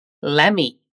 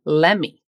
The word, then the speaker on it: let me: Novamente aqui, nós temos aquela junção do let com o me, que, como você acabou de ver, é pronunciada lemme.